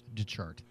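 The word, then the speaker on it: de Chartres: There's more letters to it, but it's pronounced Detchart.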